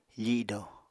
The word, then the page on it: fido: apatani